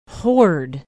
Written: hoard

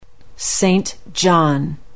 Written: Saint John

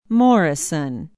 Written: morrison

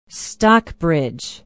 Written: stockbridge

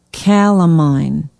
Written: calamine

